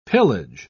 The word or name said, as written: pillage